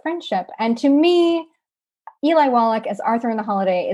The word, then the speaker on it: Wallach: Wallach, yes, I believe that's the pronunciation.